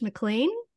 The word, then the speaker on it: McLean: Today we've got Josh McLean.